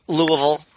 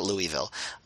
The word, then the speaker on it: Louisville: I'm a Louisville guy. Louisville, right? That's how you pronounce it. Louisville, yeah. Not Louisville.